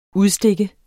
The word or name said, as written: udstikke